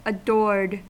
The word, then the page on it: adored: American English